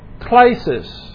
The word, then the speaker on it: klesis: First of all, you have ek, which means out from or out of, and you have klesis. Klesis is spelled K-L-E-S-I-S. And it comes from the root word kaleo, K-A-L-E-O, and it means to call.